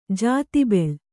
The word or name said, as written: ಜಾತಿಬೆಳ್